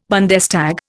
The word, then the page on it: Bundestag: Indian